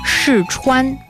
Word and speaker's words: 试穿: 试穿。